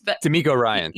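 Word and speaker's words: DeMeco Ryans: I know D'Amico, is that how you say his name? Ryan? Like he's D'Amico Ryan.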